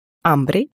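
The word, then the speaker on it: hombre: Хотя поначалу некоторые звуки могут вызывать трудности, например, буква «h» в испанском языке вообще не произносится. «Ола» читается как «ола», «амбре» как «амбре».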